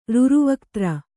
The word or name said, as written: ರುರುವಕ್ತ್ರ